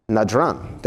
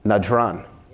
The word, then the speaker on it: Najran: Najran, thank you, sir. Najran.